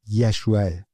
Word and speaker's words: یشوع: تلفظ درست نام عیسی در عبری یشوع، همون یشوع، همون نامی که در عهد عتیق برای یوشع به کار رفته.